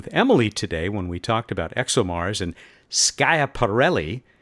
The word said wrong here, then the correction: Schiaparelli: I think I got that right, or being married to an Italian woman as I am, I will just excuse it by saying you use the American pronunciation. – Yes, I apologize for any offense I caused. Schiaparelli? – Yeah, that's a big improvement.